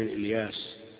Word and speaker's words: إلياس: إلياس بكسر الهمزة وفتحها ابن مضر ابن نزار ابن معد ابن عدنان.